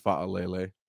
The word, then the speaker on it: Fa'alele: In case for anyone wondering, that is how you say his last name. It's Fa'alele.